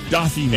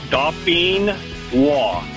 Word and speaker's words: Dauphinois: It's, like I said, it's French. It's Dauphinois. – Dauphinois. – And Dauphinois.